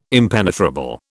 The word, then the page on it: impenetrable: British English